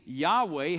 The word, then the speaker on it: Yahweh: It's a Hebrew word, to be totally honest with you, we're not exactly sure what the pronunciation is supposed to be. And when you transliterate it into English, we typically translate it or speak it one of two ways. We would either say the name Yahweh.